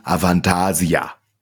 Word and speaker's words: Avantasia: Avantasia. Habe ich das jetzt richtig gesagt? Ja, damit würde ich sagen, Avantasia. – So. Das ist schon richtig, wie du sagst, auf jeden Fall.